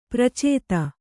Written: ಪರಿಚಿತಿ

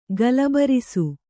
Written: ಗಲಬರಿಸು

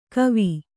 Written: ಕೋವ

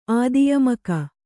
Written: ಆದಿಯಮಕ

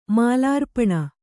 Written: ಮಾಲಾರ್ಪಣ

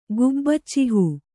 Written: ಗುಬ್ಬಚ್ಚಿಹೂ

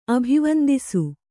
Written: ಅಭಿವಂದಿಸು

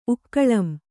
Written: ಉಕ್ಕಳಂ

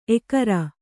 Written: ಏಕಾರ

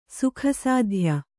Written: ಸುಖಸಾಧ್ಯ

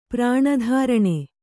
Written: ಪ್ರಾಣಧಾರಣೆ

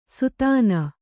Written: ಸುತಾನ

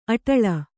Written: ಆಡುಳ್ಳಿ

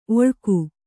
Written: ಒಳ್ಗು

